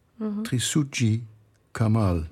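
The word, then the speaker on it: Trisutji Kamal: But today you brought music from an Indonesian composer, Trisuji Kamal. – Yes. – Do I pronounce it right? – Yes, that's correct.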